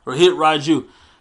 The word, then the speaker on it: Rohit Raju: Rohit Raju. That's how you say it? – Yes. – Rohit Raju.